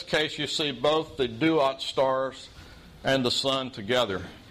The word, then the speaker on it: Duat: The star is also a hieroglyphic symbol, and it stands for a word that's pronounced Duat. Duat is the Egyptian word for netherworld or for the afterlife.